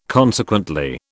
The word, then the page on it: consequently: British English